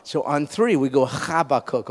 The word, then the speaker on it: Habakkuk: It's called Habakkuk. It's actually in Hebrew pronounced Habakkuk, Habakkuk.